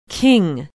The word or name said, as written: king